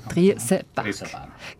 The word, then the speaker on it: Triesenberg: Er wohnt in Triesenberg, habe ich es richtig gesagt? Ja, Triesenberg.